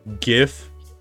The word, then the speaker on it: GIF: Okay, so this is kind of like a group thing that I want to talk about, because like GIF is pronounced with a hard G, like yeah, it is.